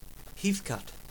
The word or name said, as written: Heathcote, Victoria